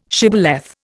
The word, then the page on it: shibboleth: Indian